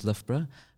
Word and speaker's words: Loughborough: If you see the word Loughborough anywhere, it's very rare that anyone can actually pronounce it because it's got the same letters twice, but in the same order, but with two different pronunciations. So it's quite a tricky one.